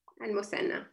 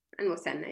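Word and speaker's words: Al-Muthanna (المثنى): I don't know if I'm going to say it correctly. Al-Muthanna? – Al-Muthanna, yeah. – Al-Muthanna? – Al-Muthanna, yes.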